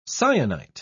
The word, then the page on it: syenite: British English